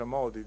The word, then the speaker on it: Modi: si pronuncia Modi diciamo in India,